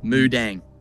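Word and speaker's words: Moo Deng: Moo like a cow. D-E-N-G. Mudang. – Mudang. Okay. Yep. Okay. Now, Mudang. – You tell me you know Mudang. – I have a Mudang shirt.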